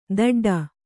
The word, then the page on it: ದಡ್ಡ: daḍḍa